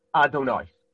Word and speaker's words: Adonai: We pronounce the shem yud kei vav kei as Adonai.